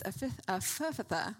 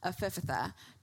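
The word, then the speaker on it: Ephphatha: Ephaphatha. The way that you... the way that you pronounce this is ephaphatha.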